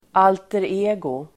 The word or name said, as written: alter ego